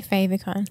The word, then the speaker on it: favicon: I just say favicon, like favorite. – Yeah, because it's favorite thing. Icon.